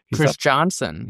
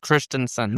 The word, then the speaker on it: Christiansen: F. Melius Christiansen. – Christiansen. – Yes. – That's good to know. I've pronounced so many names wrong when I do this show because I thought it was Christiansen. So, Norwegian.